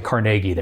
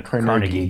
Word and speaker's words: Carnegie: Like, they pronounce it Carnegie there. If you pronounce it Carnegie, you get yelled at.